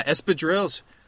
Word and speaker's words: espadrilles: Yeah, espadrilles.